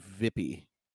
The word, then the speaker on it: wypij: It ends that way, and it would actually be pronounced in Poland as Vippy.